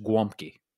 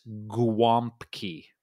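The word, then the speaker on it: gołąbki: It's actually, it looks like golabki. So it's golabki, golabki, but it's pronounced guomki. – Huh. Okay. See? – An alternate spelling could be golumpki, which is golumpki. But again, no matter how it's spelled, it is guomki.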